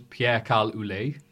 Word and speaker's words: Pierre-Carl Ouellet: Which, let me think, it's Walter versus Pierre-Carl Ouellet. – Mm-hmm. – That's how to pronounce his name, it's French.